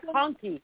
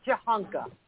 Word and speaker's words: Jakhanke: Jakunky. It sounds like Jakunky, but it's probably not how you pronounce it. Hold on, let me see if it's got a little Jahanka. It's Jahanka. Okay, whatever.